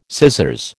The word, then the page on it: scissors: American English